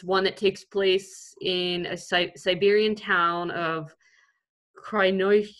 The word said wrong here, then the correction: Krasnoyarsk: I definitely said that wrong. Krasnoyarsk. There we go. That sounds right.